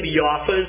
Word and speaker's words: Jaffa: As in a certain city of Jaffa is the proper pronunciation.